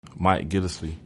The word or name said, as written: Mike Gillislee